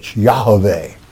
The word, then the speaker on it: Yahweh: So, but it is Y, in the Hebrew tongue, Yah, which is Y-A-H, not J-A-H, Y-A-H. There's no J's in the Hebrew alphabet. Y-A-H-A-V-E-H, Yahweh, okay? The correct pronunciation of the sacred name.